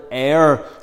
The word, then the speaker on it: heir: By the way, boys and girls, you might not know the word heir. It sounds a bit like the air we breathe, but it's spelled H-E-I-R.